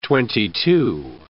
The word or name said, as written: twenty-two